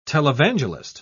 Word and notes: televangelist: British English

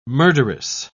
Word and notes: murderess: British English

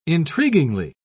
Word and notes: intriguingly: British English

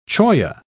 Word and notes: cholla: British English

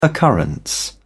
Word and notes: occurrence: British English

